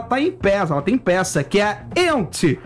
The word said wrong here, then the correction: Yentl: in Concert. – Yentl. – Eu vou fazer com pronúncia Yentl in Concert.